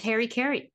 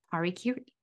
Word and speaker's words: harakiri: I always thought it was Harry Carey, but I looked it up. It is not. That's a mispronunciation. Harakiri.